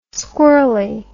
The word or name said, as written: squirly